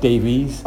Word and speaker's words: Davies: Firstly, I'd like to say you've pronounced my name right, Davies. – Oh, yeah, Welsh. – With the E, of course. – Well, have you got Davis a lot? – Yeah, of course.